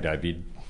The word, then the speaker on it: David: I'm saying David. – Yeah, all right. You don't pronounce the D, you ignoramuses.